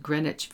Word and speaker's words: Greenwich: A neighborhood in Manhattan called Greenwich Village, note the pronunciation, is also called just the Village.